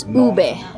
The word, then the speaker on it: ube: It's ube.